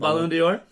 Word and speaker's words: Ballon d'Or: Ballon d'Or? – Thank you.